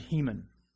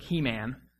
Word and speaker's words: Heman: This morning we're going to see a man named Heman. It looks like He Man, it's pronounced Heman.